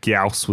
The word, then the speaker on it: Gyaos: Now, how you say it. – Gauss. I think we'll be better than we think we are. – Yeah. Who came up with this monster name and was like, G-Y-A-O-S, that's a great name for a monster.